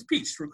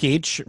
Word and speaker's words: Pietsch: Uh, Carey Pietsch, uh, and am I saying that right? It's Pietsch, correct? – Pietsch, right? Uh huh.